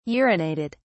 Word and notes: urinated: British English

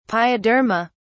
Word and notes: pyoderma: British English

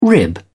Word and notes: rib: British English

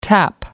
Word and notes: tap out: American English

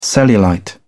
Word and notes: cellulite: British English

